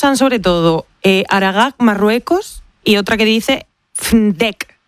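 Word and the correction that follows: Fnideq: que es un poco Fnidek, Fnidek. – Es un poco complicado de pronunciar.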